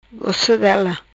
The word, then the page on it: Gossodela: patua